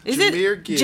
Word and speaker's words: Jahmyr: It's Jahmyr Gibbs? – Yes. – Jahmyr Gibbs, running back.